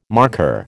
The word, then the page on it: markhor: American English